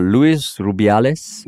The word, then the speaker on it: Luis Rubiales: Is that Luis Rubiales? – Yes. – I think that's how you pronounce his name.